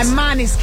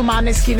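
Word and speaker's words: Måneskin: Cioè, è Maneskin. – Scritto Maneskin, però in realtà sai che si pronuncierebbe Mone.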